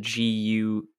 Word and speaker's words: Gyutaro: And to answer the question, apparently he did say his name is Gyutaro is how I would pronounce it. Gyutaro.